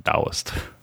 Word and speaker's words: Taoist: And we know for a fact that that's pronounced Taoist. – Taoist. Oh, that's a T there.